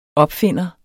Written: opfinder

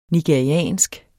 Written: nigeriansk

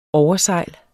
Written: oversejl

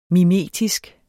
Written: mimetisk

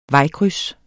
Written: vejkryds

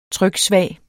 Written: tryksvag

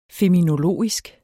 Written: feminologisk

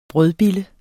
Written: brødbille

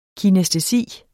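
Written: kinæstesi

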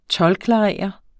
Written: toldklarerer

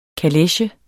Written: kaleche

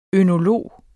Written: ønolog

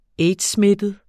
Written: aids-smittet

